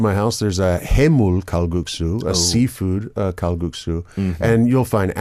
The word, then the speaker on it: Kalguksu: Kalguksu.